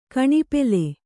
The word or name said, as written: ಘನೋಪಲ